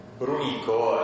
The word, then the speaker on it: Brunico: Comunque sì, Brunico è, sì, diciamo, grammaticalmente corretta perché l'accento va sulla penultima sillaba.